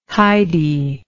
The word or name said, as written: hidy